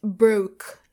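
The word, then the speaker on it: broque: It's broke. I will spell it. It's B-R-O-Q-U-E.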